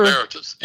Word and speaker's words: emeritus: Emeritus.